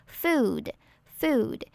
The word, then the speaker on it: food: 发音的部分，我们首先来看到 food 这个字，要注意它的 oo 比较长，那在念的时候嘴型要嘟成 u 的样子，然后它的发音呢是介于 u 跟 u 之间，像是两个的合体 food food，